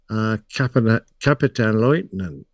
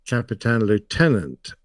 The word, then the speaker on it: Kapitänleutnant: And it's a rank in the German military that I believe is correctly pronounced, Kapitänleutnant. And I say Kapitän Lieutenant.